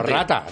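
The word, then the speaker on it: ratte: Bueno, pero, pero rata, rata. Se, acaba con, una errate, se escribe. – Ah, rate, rate. Y se pronuncia rat. R-A-T-E. – T-T-E.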